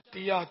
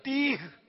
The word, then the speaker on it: تیہ: تیا چوتھا پانچا. – میدان تیا میں تیا تیا نہیں تھا تیا, وہ اصل تلفظ ہے تیہ, تیا نہیں ہے تیہ, یہ یاد کر لیں.